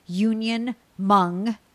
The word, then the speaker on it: Union Hmong: And the same handle on social, at unionmong, the H is silent, kitchen, at unionmongkitchen.